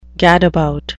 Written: gadabout

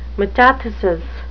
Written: metathesis

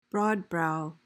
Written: broadbrow

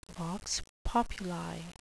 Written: vox populi

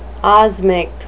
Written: osmic

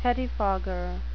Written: pettifogger

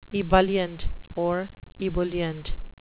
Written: ebullient

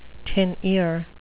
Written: tin ear

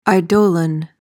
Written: eidolon

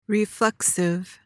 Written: reflexive

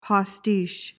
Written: postiche

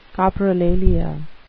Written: coprolalia

